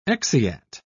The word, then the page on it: exeat: British English